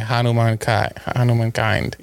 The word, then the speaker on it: Hanumankind: Oh yeah, Hanumankind. – Yeah, that's I wasn't wrong. That's how he pronounces it.